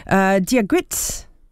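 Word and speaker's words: Dia dhuit: Now, let's see if I can get this right. Diagrit, maybe. That's how you say it. Gaelic for hello, apparently.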